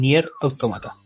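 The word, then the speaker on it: NieR:Automata: Nier, al revés, Nier Automata. Vamos entonces con esta canción de ese juego. Que la disfruten. una de las canciones de este juego, mm, Nier Automata. Ahora se lo dije bien.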